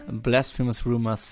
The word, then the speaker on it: Blasphemous Rumors: Das war Blasphemous Rumors von Depeche Mode, was ich diesmal richtig ausgesprochen habe, wie mir mal meine beiden Mitstreiter gerade bestätigt haben.